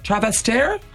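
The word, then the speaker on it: Trastevere: Tell Maria she has to look up a neighbourhood called Travestere. Travestere? Have I pronounced that correct? – Yes.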